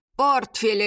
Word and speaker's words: портфель: «Портфель это!» Уверенно сказала Вера Игнатьевна, сделав ударение на первом слоге.